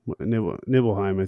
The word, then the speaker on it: Nibelheim: How do you pronounce Nibelheim? Nibelheim, I think.